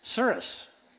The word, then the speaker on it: Souris: How do you say that? The Souris River? Souris? Souris. Okay, got to get the right North Dakota pronunciation.